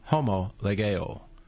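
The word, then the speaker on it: homologeo (ὁμολογέω): You would pronounce it like this, homologeo.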